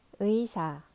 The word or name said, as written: 의사